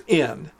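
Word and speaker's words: in (ἐν): It's pronounced in.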